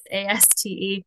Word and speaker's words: Aste: My handle is Rebecca Lee Aste, and I'm sure Jesse will put it in the show notes, but my last name is hard to pronounce. My husband's Latino, but it's Aste.